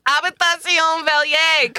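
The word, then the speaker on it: Habitation Velier: You still said it wrong. – All right. – Hebanishari Bene Pajupiter. – I just love that for once I'm not the person correcting people's pronunciations. It can just sit back and let someone else do it. – It's Habitation Valier. Come on.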